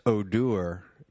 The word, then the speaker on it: Odor: Spelled Odor. – Yeah. – Pronounced Odor.